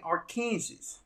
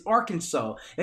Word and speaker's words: Arkansas: They start saying Arkansas. I say, that's not how you pronounce that. I said, it's Arkansas. They said, yeah, but it's spelled like Kansas, but they put R in front of the Kansas.